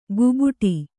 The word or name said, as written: ಗುಬುಟಿ